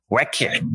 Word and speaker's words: Wrecker: Wrecker. Pero lo menciona bien, bien, un poquito diferente, probablemente por el acento inglés, pero la E la pronuncia como I.